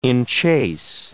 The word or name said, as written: enchase